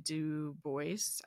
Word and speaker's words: Du Bois: And is about W.E.B. Du Bois. I think the S is pronounced for some reason. I didn't realize that until recently.